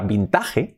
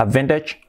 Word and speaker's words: vintage: Vintage, incluso la T la hacemos más suave, vintage, vintage, vintage.